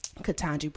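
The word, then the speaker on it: Ketanji: But listen, honestly, it's unfortunate what with what Katanji, say her name for me. – Katanji Brown Jackson.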